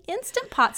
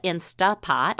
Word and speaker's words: Instant Pot: Well, one of the first things I need to do in my update is correct my pronunciation of the appliance back in episode 18. That shows you how little I knew. At that point, I was calling it an Instapot.